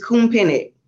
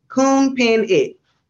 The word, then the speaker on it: Khounphinith: It's just Khounphinith. Khounphinith.